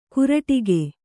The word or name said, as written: ಕುರಟಿಗೆ